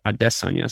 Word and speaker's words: Adesanya: They kind of drilled Adesanya into my head, but he keeps saying like it's Adesanya, so I'm trying.